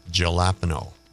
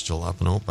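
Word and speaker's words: jalapeño: But Albert John Saxby, here he is with a song called Jalapeno. Well, if you're a Trailer Park Boy, maybe it's Jalapeno, but it's Jalapeno. The J is silent.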